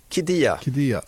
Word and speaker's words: Qiddiya: Qiddiya, tror jag att det uttalas.